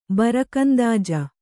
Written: ಬರಕಂದಾಜ

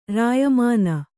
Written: ರಾಯಮನೆ

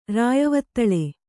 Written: ರಾಯವತ್ತಳೆ

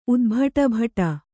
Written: ಉದ್ಭಟಭಟ

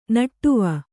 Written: ನಡುವು

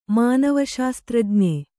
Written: ಮಾನವಶಾಸ್ತ್ರಜ್ಞೆ

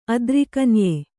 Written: ಅದ್ರಿಕನ್ಯೆ

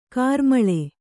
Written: ಘೋರಾಮ್ಲಿ

